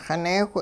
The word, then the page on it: xineewh: hupa